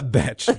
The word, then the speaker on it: betch: A betch, B-E-T-C-H. – Yeah. – That is how you say it.